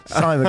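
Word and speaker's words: CIMON: It's spelled C-I-mon. Simon, because it's sort of cyborg.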